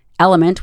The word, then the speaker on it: LMNT: Thank you to Element for sponsoring this podcast, and Element, which is spelled L-M-N-T,